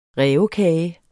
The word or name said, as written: rævekage